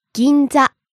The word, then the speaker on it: Ginza: Ginza. – Ginza. Shopping district in Tokyo. – Ginza. Ginza. – Rising on the second syllable N and staying high. – Ginza. – Next. – Eiga. – Movie. – E-I-G-A.